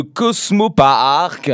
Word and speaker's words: Cosmo Paark: Bah oui, pour être précis comme ça dans la musique, hein, du nom de Cosmo Park. Alors bon, pourquoi je le dis comme ça? Ça s'écrit C-O-S-M-O-P-A-A-R-K.